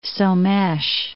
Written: Somes

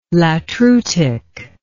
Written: latreutic

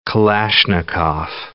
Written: Kalashnikov

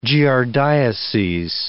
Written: giardiases

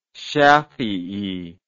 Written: shafii

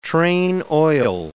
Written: train oil

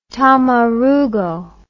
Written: tamarugo

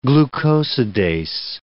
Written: glucosidase

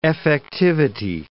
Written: effectivity

